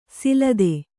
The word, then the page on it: ಸೆಲದಿ: kannada